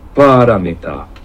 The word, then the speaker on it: paramita: paramita。 – 咁喺 A 字上面有一畫嘅咧，就係、是、應該要發音長少少嘅，咁所以 Google 嗰個讀音咧應該係比較正確。咁呢一個 paramita 咧，咁就係四個音節啦，咁所以佢係譯做波羅蜜多啦，咁係一個音譯啦，好明顯。